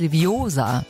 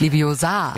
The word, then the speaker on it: Leviosa: Es heißt Leviosa, nicht Leviosa.